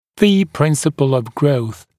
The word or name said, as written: V-principle of growth